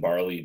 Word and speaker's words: Barli: It's Jonathan Barley, B-A-R-L-I.